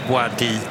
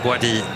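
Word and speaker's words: Bouaddi: Spelar just nu Ayoub Boadi, eller Boadi i Lille. Boadi kanske ska uttalas.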